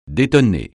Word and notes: détonner: francais

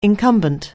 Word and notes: incumbent: anglais

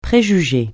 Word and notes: préjugé: francais

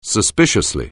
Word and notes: suspiciously: anglais

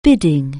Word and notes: bid: anglais

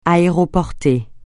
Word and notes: aéroporté: francais

